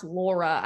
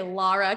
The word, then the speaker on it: Laura: I always say it's pronounced Laura. I always say Laura because I'm from Boston and people are always like, is it like L-A-R-A? I'm like, no, it's L-A-U-R-A. It's just how I say it.